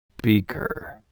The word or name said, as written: beaker